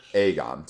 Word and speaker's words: Aegon: And I think it's fairly obvious from the way, like, Hannah, at the end of season 2, Egon... How do you say that name? Sorry, I'm butchering. – Aegon. – Aegon.